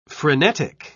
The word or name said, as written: frenetic